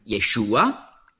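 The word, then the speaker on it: Yeshua (ישוע): Und so hat der Herr Jesus eben als Rufnamen den Namen Jesus, auf Hebräisch ausgesprochen Yeshua.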